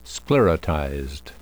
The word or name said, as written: sclerotized